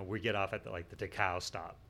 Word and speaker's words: Dachau: Dachau? I don't know if I'm saying it right. Dachau. – Dachau. – Yeah, yeah. Dachau. That's what it was.